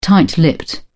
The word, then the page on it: tight lipped: British English